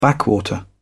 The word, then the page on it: backwater: British English